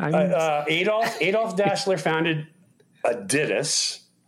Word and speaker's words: Adidas: Do you know we have been pronouncing that name wrong all these years? The name, because it's a German company, the name, it's actually pronounced Adidas. – Adidas. – Adidas. Don't that's how that. You print that. That's the pronunciation.